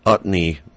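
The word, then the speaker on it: Utne: And it was in, I think it's pronounced Utney Reader, Utney Magazine, which has a number of usually liberal points of view on things.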